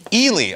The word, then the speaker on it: Ely: Ely.